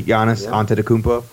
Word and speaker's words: Giannis Antetokounmpo: Giannis Antetokounmpo. – Okay, yeah. – There you go. – I pronounced that correctly. Giannis Antetokounmpo. – That's it. He nailed it.